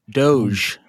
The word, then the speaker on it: Doge: Is it the dog coin or the Dodge coin? – Doge.